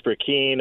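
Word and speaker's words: Brecheen: It was Brashear who put you up. I'm pronouncing his name wrong. Josh. Oh, I can't remember his last name. – Josh Brekeen, a new member from Oklahoma.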